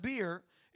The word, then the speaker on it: Be'er: Speaking of the coronavirus, this is not that kind of beer. It's pronounced Be'er, something like that.